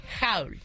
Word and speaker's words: haul: Entonces, ¿cuál es la pronunciación correcta? – Haul. – Haul. Y se escribe H-A-U-L. Haul. Así es.